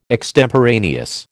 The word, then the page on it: extemporaneous: American English